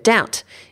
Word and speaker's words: doubt: So let's take the example of the word doubt, meaning to be uncertain about something. In English, we spell it with a silent B, which gives you a little clue as to where it comes from, which is the number two.